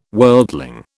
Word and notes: worldling: British English

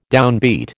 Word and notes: downbeat: American English

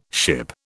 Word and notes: ship: British English

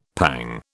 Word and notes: pang: British English

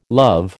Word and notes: love: American English

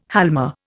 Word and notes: halma: Indian

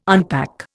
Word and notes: unpack: Indian

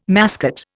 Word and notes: mascot: Indian

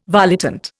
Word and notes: volitant: Indian

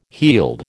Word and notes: heald: American English

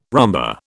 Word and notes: rumba: British English

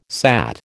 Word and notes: sat: American English

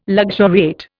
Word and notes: luxuriate: Indian

